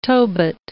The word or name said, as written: Tobit